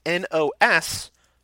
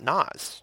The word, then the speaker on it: NOS: So Michael says, subject line, it's pronounced N-O-S, not Nas.